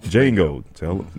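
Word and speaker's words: Jango: Django. Make sure they know, because I'll say it wrong. – Is it Django with a D? – No. It's J-A-N-G-O.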